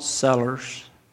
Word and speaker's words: cellar: they call them salt cellars, if I pronounce it right, C-E-L-L-A-R, that would be filled with salt and they'd have a spoon in them, and they would set it on the table.